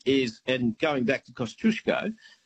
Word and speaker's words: Kosciuszko: There's one Australian place that is mispronounced every day of the week, and that is Kosciuszko, as in Mount Kosciuszko, the highest mountain in Australia, right? Mm-hmm. That's how the Poles would pronounce it, not Kosciuszko.